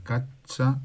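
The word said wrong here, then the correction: Czaja: Cacha. – Chaya. Chaya. madre mía.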